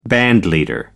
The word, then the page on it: bandleader: American English